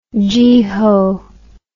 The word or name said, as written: geehaw